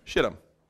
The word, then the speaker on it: Shittim: I'm going to pronounce it like it's actually pronounced. Shittim. Okay? It's how it's pronounced, okay?